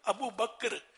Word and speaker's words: بکر: لیکن صحیح تلفظ ابو بکر کاف ساکن ہے, کاف پر زبر نہیں ہے.